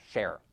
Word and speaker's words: Schaerr: And it His name is spelled in a very funny way. – Yeah, S-C-H-A-E-R-R, Scher. And